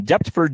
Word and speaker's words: Deptford: Last one for the week, we've got the Deptford Show on April 27th through the 28th at the Deptford Mall in Deptford, New Jersey. That's a complicated pronunciation. They put that PTF all together, and it's like going on here.